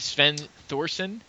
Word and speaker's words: Sven Thorsen: That's Sven Thorsven. It's Sven Thorsen. That's how you pronounce it.